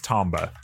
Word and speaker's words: Tomba: Specifically, they mentioned Tomba. I forget how to pronounce it. Parappa. – I think it's Tomba. – Is it Tomba? Said Tomba. Okay.